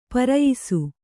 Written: ಪರಯಿಸು

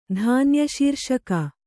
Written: ಧಾನ್ಯಶೀರ್ಷಕ

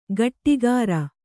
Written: ಗಟ್ಟಿಗಾರ

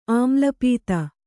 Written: ಆಮ್ಲಪಿತ್ತ

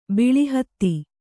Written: ಬಿಳಿಹತ್ತಿ

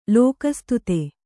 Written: ಲೋಕಸ್ತುತ

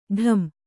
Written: ಢಂ

